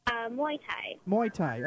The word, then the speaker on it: Muay Thai: So I guess my first question to you is, and it's the fight tomorrow in Richmond is Muay Thai. Am I pronouncing that correct? – Muay Thai.